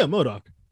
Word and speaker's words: MODOK: but MODOK? – Yeah, MODOK.